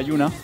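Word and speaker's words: Yonah: Y la historia de momento es de Nier, que sería el protagonista, que le cambia el nombre, pues tiene una hija que se llama Yona, que se pronuncia Yuna, y que está enferma, tiene una enfermedad que se llama Dark Scroll, que le salen símbolos en la piel y se mueren.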